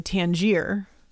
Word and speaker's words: Tangier: I think Tangiers. – Tangier. Well, there's no S on the end.